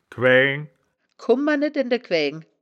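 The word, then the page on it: quääng: wittlicher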